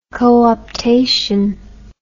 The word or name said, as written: cooptation